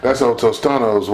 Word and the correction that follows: Totino's: whatever, pizza Totino's.